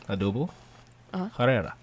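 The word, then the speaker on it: Odúbel Herrera: That's Adubo Herrera. Like, that's how you say his name. – Say it again. – Adubo Herrera.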